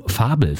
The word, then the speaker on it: Fabel: Die neuseeländische Band aus Berlin wird am kommenden Freitag endlich das zweite Album Fabel veröffentlichen, das man in der Tat auch deutsch aussprechen muss.